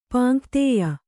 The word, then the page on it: ಪಾಂಕ್ತೇಯ: pāŋktēya